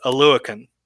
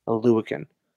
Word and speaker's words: Oluokun: The last name, I even butchered it earlier today, but it's Oluokun. – Oluokun. – Oluokun.